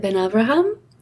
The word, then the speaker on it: ben-Avraham: I usually pronounce it Ben-Avraham. My partner, David, has given up trying and just is like, I'm David Ben-Avraham.